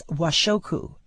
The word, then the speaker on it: washoku: And Japanese home food evidently is referred to, and I may mispronounce this, as washoku. It's W-A-S-H-O-K-U.